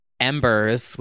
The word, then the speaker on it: EMBRZ: Embers, which is spelled EMBRZ, a song called Visions.